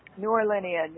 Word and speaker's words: New Orleanian: How do you pronounce it? You're a New Orleanian? – New Orleanian, yes. – Oh, my God, I got that one on the first go.